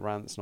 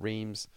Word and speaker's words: Reims: I'm going to keep pronouncing it Rance, not Reims.